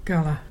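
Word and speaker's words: Garla: Gala. There's fire. And to spell that, there's G-A-R-L-A. Can you say Gala?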